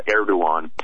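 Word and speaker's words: Erdoğan: It looks like it's spelled Erdogan, but it's pronounced Erdogan.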